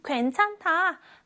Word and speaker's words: 괜찮다: 이 낱말에는요, 겹받침이 있습니다. 여기 니은 히읗이 있죠? 이것에 주의하면서 읽어볼게요. 괜찮다, 괜찮다. 제일 처음 괜은 그대로 발음을 하면 됩니다. 괜. 그런데 찬다에서 니은 히읗 겹받침이 있죠? 이런 경우에는 둘 중에 하나의 소리만 나는데 대부분 앞에 있는 자음 괜찮. 니은의 소리, 니은이 소리가 납니다.